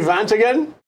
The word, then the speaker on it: Vantieghem: Oh, that's Henri Vantagen. Did I say that right? – Yeah, okay. – Perfect.